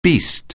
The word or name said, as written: beast